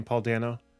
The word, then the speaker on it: Paul Dano: And Paul Dano, again, is that how you say his name, Paul Dano? – I believe so.